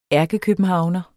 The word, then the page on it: ærkekøbenhavner: [ ˈæɐ̯gəkøbənˌhɑwnʌ ]; [ ˈæɐ̯gəkøbənˈhɑwnʌ ]